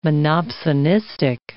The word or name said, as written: monopsonistic